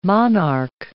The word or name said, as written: monarch